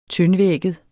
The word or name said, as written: tyndvægget